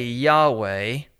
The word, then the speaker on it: Yahweh: We say Yahweh. It is only an approximate pronunciation because the original has been lost, but that is the name.